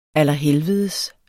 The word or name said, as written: allerhelvedes